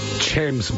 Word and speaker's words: James: There's every chance that Sam Neill would have pronounced James Mason's first name the way that his very good friend John Clark did. – Mm-hmm. – Chams Mason. C-H-A-M-E-S.